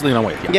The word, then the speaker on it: Waithe: Am I saying that right? Lena Waithe. – Yes, Lena Waithe.